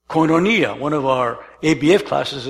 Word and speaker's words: koinonia: The word fellowship, it comes from the translation of a word called koinonia. Could you pronounce that for me? Koinonia, from the word koinos. Koinos, which means common.